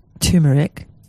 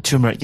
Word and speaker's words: turmeric: You have to be quite careful with turmeric. Am I saying it right now? – Turmeric, yes.